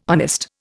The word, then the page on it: honest: Indian